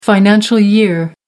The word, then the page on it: financial year: American English